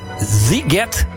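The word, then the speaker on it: Sziget: Komende zomer staan ze op het festival Ziget. Zeg ik dat goed, Rianne? – Zeker, ja.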